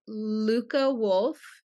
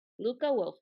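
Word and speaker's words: Lykke Wulff: I believe it's pronounced Lyke Wolf, but it's spelled L-Y-K-K-E. – Okay. – And then W-U-L-L-F. – That's what I thought. Lyke Wolf, women's clothes, handmade.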